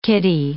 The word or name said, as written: kiddie